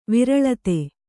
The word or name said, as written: ವ್ರೀಳಿತ